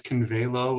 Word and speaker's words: Convalo: He also is a narrative coach and does consulting through his company, Convala. Am I saying that correctly? – Well, that's Convalo, which is sort of a Latin word, which means to grow stronger.